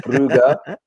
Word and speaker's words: Brugge: Club Brugge, I've been learning off Vincent Saw, so I know how to pronounce it now. So it's Club Brugge 2-1.